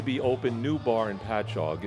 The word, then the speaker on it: Patchogue: Patchogue. Did I say that right? – Patchog. – Patchog. – Patchog. There we go.